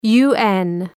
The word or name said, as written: UN